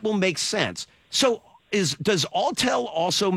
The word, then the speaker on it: Autel: Are you hip to, I hope I'm pronouncing it right, Autel devices? – I know they make, like, small drones. – Autel does?